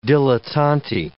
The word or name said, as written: dilettanti